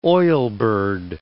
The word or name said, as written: oilbird